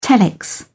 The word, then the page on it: telex: British English